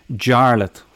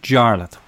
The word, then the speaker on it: Jarlath: Jarlath. What? And so goes the dance of my life. What is your name? Jarlath. What? Jarlath. Say that again. Jarlath. How do you spell that? J-A-R-L-A-T-H.